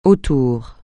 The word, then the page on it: autour: francais